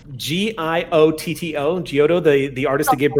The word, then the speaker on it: Giotto: Saru uses the work of Giotto. I hope that's correct. The artist that gave birth to the Renaissance. Sam, can you check me on that? G-I-O-T-T-O.